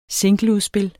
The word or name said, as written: singleudspil